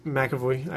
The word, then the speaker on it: McAvoy: I feel like Christian Bale has the more of the attitude, but James McAvoy is McAvoy. I don't know why I keep saying his name wrong.